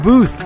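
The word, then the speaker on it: Boothe: For that, love and sounded Ken Booth. That's Booth with an E. The E is silent.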